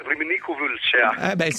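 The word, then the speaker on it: Râmnicu Vâlcea: Allora, tu sei stato in questo villaggio, lo conosci, Ramniciu Valcea, è questa la giusta pronuncia? – No, la pronuncia esatta è Ramniciu Valcea.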